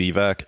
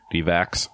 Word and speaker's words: Divac: It is, of course, Vlade Divac, a.k.a. Vlade Divac. I don't know why sometimes people pronounce it Divac. There's no S on there, but I mean, I said it when I was little.